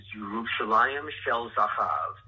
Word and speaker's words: Yerushalayim Shel Zahav: The pronunciation of that song is Yerushalayim Shel Zahav, and that's the Hebrew for it.